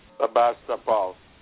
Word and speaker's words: Sevastopol: Sevastopol.